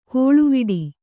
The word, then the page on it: ಕೋಳುವಿಡಿ: kōḷuviḍi